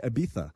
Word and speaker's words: Ibiza: With European accent, by the way. – Right, yeah. – You have to say Ibiza.